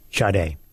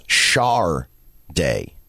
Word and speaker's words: Sade: Now, the internet does say there was an album or something printed called by Epic Records called Pronounced Shar Day.